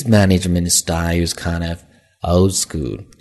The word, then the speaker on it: old school: 那注意它的发音，old school。那中间的字母 d 往往读快的时候呢，读得很轻，几乎是没有发出来。 old school，所以呢，有的时候你听到是这样的一个发音。